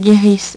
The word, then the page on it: guerisse: French